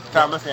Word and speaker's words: Falmouth: For example, today we're going to go to Falmouth or Falmouth? – Falmouth.